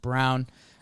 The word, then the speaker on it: Braun: And then Brown, his last name looks like Braun, but I believe it's pronounced Brown.